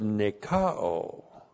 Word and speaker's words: nikao: So it comes, this is the participle of the verb nikao. N-I-K-A, long O, nikao.